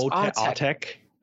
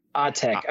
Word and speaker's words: Autechre: Are we talking about Autechre? – Yes, Autechre. Basically Autechre. – Autechre. Okay.